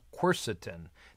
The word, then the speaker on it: quercetin: It turns out there's a really fascinating antioxidant polyphenol called quercetin. That's Q-U-E-R-C-E-T-I-N.